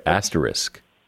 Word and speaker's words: asterisk: Asterisk. I'm sorry, asterisk. – Okay, well, so two things just happened there. At first, you said asterisk without an S, and then you said asterisk with an S.